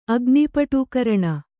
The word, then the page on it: ಅಗ್ನಿಪಟೂಕರಣ: kannada